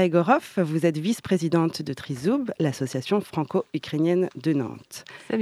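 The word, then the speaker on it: trizoub (тризуб): Le trident se prononce trizoub en ukrainien et c'est le nom de l'association franco-ukrainienne de Nantes que nous accueillons ce soir.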